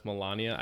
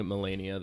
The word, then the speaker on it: Melania: So they turn into like some kind of gossipy gay from the 1920s whenever they start talking about Melania Trump. – Correct. That is exactly. – Which, by the way, what happens. I did not realize that it's pronounced Melania. I've been pronouncing it Melania this whole time.